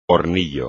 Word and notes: hornillo: espagnol

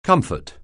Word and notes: comfort: anglais

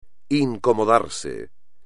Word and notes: incomodarse: espagnol

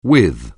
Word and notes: with: anglais

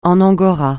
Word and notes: en angora: francais

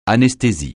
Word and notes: anesthésie: francais

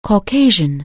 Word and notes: Caucasic: anglais

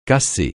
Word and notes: casser: francais

